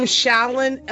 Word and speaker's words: Shalon: And I'm Shallon LT. So my name is spelled S-H-S as in Sarah, H-A, L as in Lisa, O-N like Nancy, L-T.